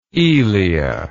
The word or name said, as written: Elea